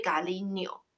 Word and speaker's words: cariño: 好，第一个比较大众化的，比较常听到的就是 cariño，这个我很常用，而且这个字我觉得超好笑。 cariño 是 C A R I N，上面有一个小毛毛虫 año 哈，所以 cariño 这样发音是 año，就是 n año o cariño cariño 是亲爱的 dear。